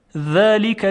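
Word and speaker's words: ذلك: خب یک مثال دیگه: ذالک الکتاب لا ریب فیه، ذالک، ذالک. دقت میکنید که صدای ذال صدای ظریفی هست.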